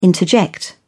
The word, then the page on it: interject: British English